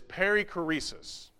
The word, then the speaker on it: perichoresis: The word is perichoresis.